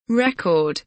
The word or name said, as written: record